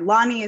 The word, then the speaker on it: Loni: Either one is fine. Loni is how we would say it in English, but there's a lot of variation.